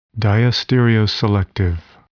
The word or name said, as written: diastereoselective